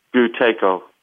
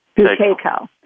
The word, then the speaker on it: Buteyko: Buteyko. – Buteyko.